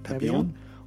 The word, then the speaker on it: Papillon: So, were they A, a pointer, B, a Pomeranian, C, a poodle, D, a Papillon, is that how you would pronounce that? – Yep, Papillon.